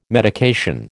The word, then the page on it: medication: American English